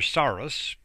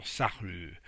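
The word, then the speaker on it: Sarrus: For instance, there's a French mathematician, Pierre de Saru, and of course, you might want to say Pierre Saurus, but that wouldn't be quite kosher. Just like you don't pronounce the S in Camus, the famous French author, you don't pronounce that either. So Saru would be the French way of saying it.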